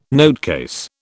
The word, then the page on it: notecase: British English